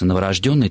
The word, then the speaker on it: новорожденный: Конечно, новорожденный только так, слово «рожденный» имеет во всех производных словах именно такое ударение: новорожденный, незаконнорожденный, мертворожденный и так далее.